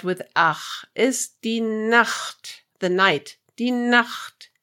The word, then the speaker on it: Nacht: This word is also good to see why it's important not to pronounce the ch as a k. Die Nacht is the night and nackt is naked.